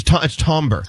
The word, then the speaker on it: timbre: It's Tombra.